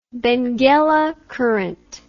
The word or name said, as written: Benguela Current